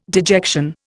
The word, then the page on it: dejection: Indian